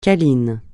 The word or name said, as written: câlin